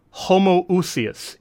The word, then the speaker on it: homoousios: When we say, like, there's, for instance, the Father and the Son are of the same substance, homoousios is how you say that in Greek.